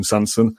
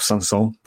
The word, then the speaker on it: Sanson: I always feel like January isn't the best time to do that, but we've been linked with Morgan Sanson. Or Sanson, probably it's pronounced.